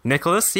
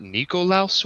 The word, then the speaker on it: Nicholas: I got Nikolaus once. Really? Like they thought I was Russian or something.